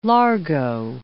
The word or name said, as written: largo